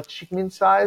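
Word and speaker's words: Csikszentmihalyi: Mikhail Chikminsai, is it? – Yes.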